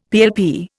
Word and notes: PLP: Indian

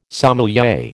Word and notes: sommelier: American English

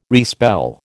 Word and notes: respell: American English

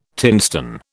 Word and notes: tinstone: British English

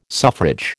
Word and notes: suffrage: American English

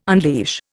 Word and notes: unleash: Indian

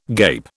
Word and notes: gape: British English

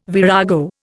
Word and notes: virago: Indian

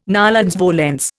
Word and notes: nolens volens: Indian

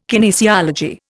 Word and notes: kinesiology: Indian

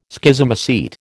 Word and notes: Schizomycete: American English

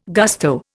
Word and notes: gusto: Indian

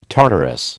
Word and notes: Tartarus: American English

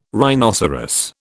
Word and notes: rhinoceros: British English

